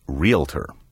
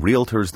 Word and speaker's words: realtor: Realtor puts the sound of the L before the A, as though the word were spelled R-E-L-A-T-O-R. The proper pronunciation sounds like the word real followed by ter, realtor.